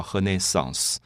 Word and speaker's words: Renaissance: 文艺复兴这个字在英文里面，我们知道叫 Renaissance，R E N A I S S A N C E。这个字其实它的字根是拉丁文，所以在法文里面也是这样，同样的拼法，只是发音不同，我们叫 Renaissance。